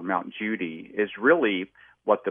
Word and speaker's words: Judi: There's also Mount Judi, or over there they call it Kudi because they can't pronounce a J. They pronounce it with a C or a K. So Mount Kudi, some people think that it's about 50 miles north of Mosul Ararat, and it's in the southwest part of Ararat.